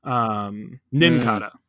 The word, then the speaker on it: Nincada: But that gets stronger when you introduce, that theory gets stronger when you introduce a little Pokemon called Cincada. Am I saying that right? Nincada. So let me say that again.